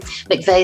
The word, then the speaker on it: McVeigh: McVeigh is spelled rather oddly, McVeigh.